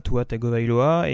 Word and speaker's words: Tua Tagovailoa: Tua Tagovailoa, et on sait que... – Ah, comment il fait exprès de le prononcer bien, parce que je l'ai mal prononcé la dernière fois.